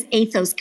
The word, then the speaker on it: Aithos: We say it as Aithos Coalition. It's like the Aithos, ethos of coalition. AI.